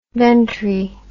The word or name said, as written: ventri